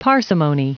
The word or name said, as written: parsimony